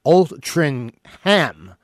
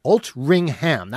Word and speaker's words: Altrincham: And Altrincham is pronounced Altrincham. Altrincham. That's how you're supposed to... It's spelled Altrincham, all right? But it's pronounced Altrincham.